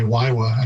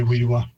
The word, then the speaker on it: Wiwa: I first heard someone say Waiwa, and I thought, well, that's completely wrong for Wiwa. But others do say Wiwa.